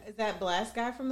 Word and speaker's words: Blxst: Is it Blast or Blackest? – Spell his name. – BLXST. – It's Blast. – Blast.